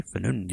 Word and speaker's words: Pneu: Es así, o Fenun, sí, Fenun, yo creo que es que se pronuncia así, P-N-E-U.